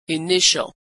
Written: initial

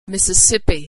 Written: Mississippi

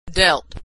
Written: dealt

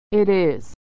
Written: it is

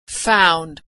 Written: found

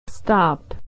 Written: stopped

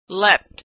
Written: leapt

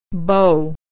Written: bow

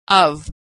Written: of

